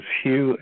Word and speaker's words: Hugh: I've had a pretty bizarre life, and my name is Hugh, H-U-G-H. The last name, Trawlson, is spelled T like Tom, R-A-U-L-S-E-N like Nancy.